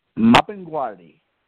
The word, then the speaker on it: Mapinguari: Mapinguari.